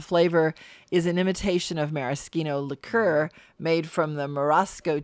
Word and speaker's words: maraschino: It is maraschino because now, so many years later, I realized the proper way to say CH in Italian. Okay? CH versus American way of saying CH. – And how do you say that? – In Italy, a C and an H always is a hard C.